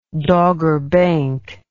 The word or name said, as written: dogger bank